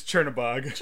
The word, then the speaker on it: Chernabog: Chernberg? How do you say? – Chernberg? No, that's the mayor of Schenectady. – Can you say his name? – No, it's Chernabog.